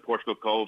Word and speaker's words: Portugal Cove: Portugal Cove, am I saying it right? – Yeah, that's Portugal Cove, St. Phillips.